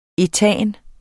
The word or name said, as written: ætan